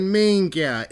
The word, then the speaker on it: minchia: Fucking Minga. It actually is spelled Minchia, but you don't say it. You say the C-H like a G. It's fucking Minga.